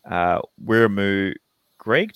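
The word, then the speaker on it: Wiremu Greig: Four pack, Regan Campbell, Gillard, and Wiramu Greg. Did I pronounce that right, Mitch? Yeah. – Yeah, pretty much.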